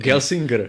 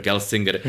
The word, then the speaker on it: Gelsinger: Ono, ono mělo úplně právě ten Pat Gesslinger, jestli ho vyslovuju správně, který teď eh, Gelsinger. – Gelsinger, který teď no.